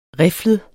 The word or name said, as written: riflet